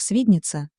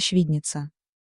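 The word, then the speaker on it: Świdnica: Она тогда жила в Польше, работала в паспортном отделе штаба советских войск, который располагался сначала в Легнице, потом в Свиднице, по-польски произносится Швидница.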